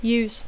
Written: use

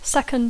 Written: second

